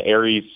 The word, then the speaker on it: Ares: Ares, and Ares, I should point out, is spelled A-R-E-S, so it's not like the constellation.